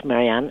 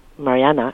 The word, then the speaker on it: Marianne: who I found out was named Marianne. Actually, Mariana is the Norwegian pronunciation, and that's what everyone, including Leonard, called her, Mariana.